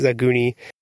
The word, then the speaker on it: Agni: And that the way that that would be pronounced in Japanese is Aguni.